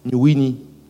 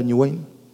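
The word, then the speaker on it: New Wine: Estaba ese ese que sabía poco inglés decía New Winnie, porque lo leía y decía New Winnie, no era New Wayne, era New Winnie, y, pero no, no sabía pronunciar. Hasta que escuchó a otro que dijo New Wayne, ah, ¿y qué significa?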